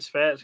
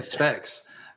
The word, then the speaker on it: spats: I think I was saying, no, I think I was saying specs. I think I was saying specs.